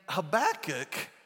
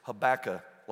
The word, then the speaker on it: Habakkuk: And Habakkuk is how you say it, by the way. When I was growing up, I thought his name was Habakkuk. Like tobacco. Okay, there's a K at the end of that.